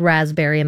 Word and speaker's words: raspberry: I got second place in the spelling bee because of the word raspberry and the silent P.